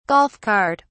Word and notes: golf cart: British English

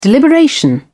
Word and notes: deliberation: British English

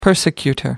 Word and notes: persecutor: American English

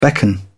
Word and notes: beckon: British English

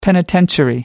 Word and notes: penitentiary: American English

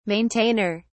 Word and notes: maintainer: British English

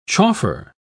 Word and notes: chauffer: British English

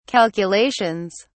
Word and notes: calculations: British English

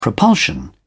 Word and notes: propulsion: British English

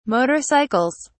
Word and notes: motorcycles: British English

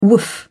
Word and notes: woof: British English

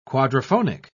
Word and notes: quadrophonic: British English

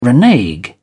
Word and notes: renege: British English

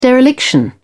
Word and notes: dereliction: British English

